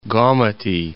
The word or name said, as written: Gomati